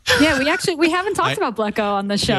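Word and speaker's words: Blekko: I can't remember if Blacko has a slash tag.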